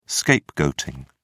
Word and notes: scapegoating: British English

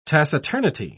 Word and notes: taciturnity: British English

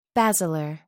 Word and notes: basilar: British English

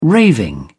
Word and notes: raving: British English

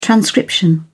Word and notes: transcription: British English

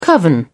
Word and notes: coven: British English

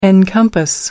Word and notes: encompass: American English

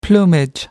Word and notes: plumage: American English

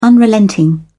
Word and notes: unrelenting: British English

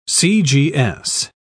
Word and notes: cgs: British English

